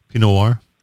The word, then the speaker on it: Pinot Noir: So we had the Herzog Lineage Pinot Noir. Is that the right pronunciation? Pinot Noir? – Yes.